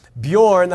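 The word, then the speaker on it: Björn: It's called Bjorn, that's how you pronounce that. It's not Bjorn, it's Bjorn Books. Bjorn is the Swedish way to say bear, and that is what my grandsons call me.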